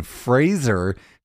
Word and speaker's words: Fraser: I was watching TikTok the other day, and Brendan Fraser corrected everybody and said it's Fraser, like Razor. That's how he pronounces his name. He said it's been pronounced wrong for years.